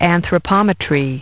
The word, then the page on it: anthropometry: British English